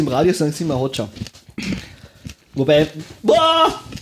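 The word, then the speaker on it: Hoxha: So spricht man denn Hotcha. – Aus. Hotcha. – Enver Hotcha.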